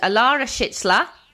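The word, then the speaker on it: Alara Şehitler: So she's called Alara Schitzler. So that's how you pronounce it. I asked Nicole Rosler.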